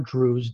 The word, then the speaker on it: Drues: So my name is Michael Drews, D as in David, R-U-E-S.